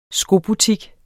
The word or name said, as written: SKOBUTIK